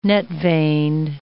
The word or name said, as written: net-veined